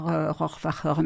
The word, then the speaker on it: Rohrwacher: À l'allemande, on doit dire Rohrwacher, mais bon, peu importe.